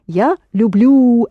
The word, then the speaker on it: люблю: Я люблю, accented on the ending.